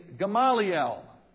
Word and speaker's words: Gamaliel: But a Pharisee named Gamaliel, that's the Greek pronunciation. In Hebrew, you'd say Gamliel.